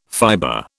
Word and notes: fiber: British English

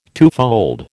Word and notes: twofold: American English

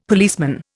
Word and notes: policeman: Indian

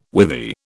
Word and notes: withy: British English

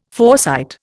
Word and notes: foresight: Indian

